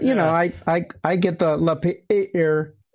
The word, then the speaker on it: Lapeer: L-A-P-E-E, two E's, R. I want to say like a Hebrew word, Lapeer. How do you guys pronounce it? – Lapeer. – Lapeer. Oh, just Lapeer. Yeah. Okay. – Lapeer. – Yeah, like it looks in American English.